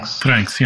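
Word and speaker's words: Vranckx: Vranks? – Vranks, yeah.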